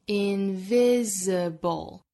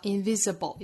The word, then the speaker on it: invisible: 最后的 invisible 也要注意字母 s 对应的是浊音 invisible 或 invisible, 也就是 s 后面的字母 i 可以读成 i, 也可以读成刷 sound invisible。好，我们来用非常慢的速度用英音来读一下这句话，我读一句你读一句。